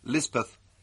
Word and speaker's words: Lispeth: The Kotgarh chaplain christened her Elizabeth, and Lispeth is the hill or Pahari pronunciation.